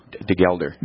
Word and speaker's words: Degelder: How do you say Jason's last name? Help me there. Jason Degeldre, who's the production manager for Marvin Windows. Any idea? Is he local? – Degelder. – Degelder.